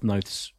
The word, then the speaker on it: Noth: If we've learned one thing this week, it's how to pronounce Christopher Noth's name. – Okay, so it's Chris Noth rhymes with both.